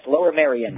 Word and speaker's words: Lower Merion: Senator Arlen Specter has said that the use of Lower Merion, is that how you pronounce it? – Yes, Lower Merion.